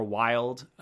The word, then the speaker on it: Wylde: If you're curious about my gender fluid stuff, you'll find Vera Wilde, V-E-R-A. The last name is spelled W-Y-L-D-E because I had to spell it in the most pretentious way possible.